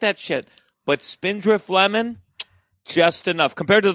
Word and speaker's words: Spindrift: is it Spindrift? Is that correct? – It is Spindrift, yes.